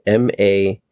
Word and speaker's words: Mamet: Writer and director of David Mamet, I think is how you say it, Mamet.